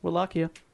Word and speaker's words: Wallachia: It's Wallachia. So if I've been mispronouncing that for an hour, I apologise, but the one translation that I found was Wallachia.